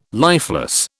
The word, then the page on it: lifeless: British English